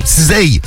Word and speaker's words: SZA: Tiens, en parlant de son, on aura du Maes, on aura du Cizei. – Cizei.